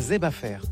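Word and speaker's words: zèb a fè: Zeb, comme herbe, à fait. – Zeb. – Parce qu'il est chargé en fer aussi. – Ah, Zeb à fer. – Ouais. On prononce pas les R ici.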